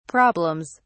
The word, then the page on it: problems: English; British English